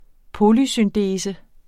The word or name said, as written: polysyndese